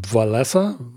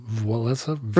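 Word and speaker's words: Wałęsa: And I guess I should be pronouncing it Walesa. Walesa. – For some reason, there's a N sound.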